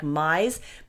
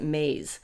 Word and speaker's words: Myss: Carolyn, it's spelled M-Y-S-S, like Mize, but when they're talking about her, they always pronounce it Maze.